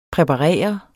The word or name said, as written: præparere